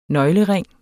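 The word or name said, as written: nøglering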